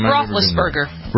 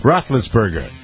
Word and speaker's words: Roethlisberger: Victoria Ben Roethlisberger. I've never really pronounced it. Is that how you pronounce his name? – Roethlisberger. – Roethlisberger.